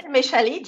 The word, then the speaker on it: Méchali: You got your PhD in microbiology at the University Pierre et Marie Curie in 1988, under the supervision of Marcel Méchali. I'm sorry if I don't pronounce the French names correctly. – No, that's good. That's perfect. It's Marcel Méchali, just as you said.